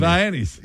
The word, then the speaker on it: Viennese: Them Vianney wings. – Did he called them Viennese, didn't he? – Viennese. – Yeah, Viennese. Love it. – That's the best. – He gets extra points for pronouncing it correctly.